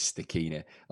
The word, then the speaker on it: Stachini: I've never heard my second name being pronounced Stachy before. The correct pronunciation is Stachini.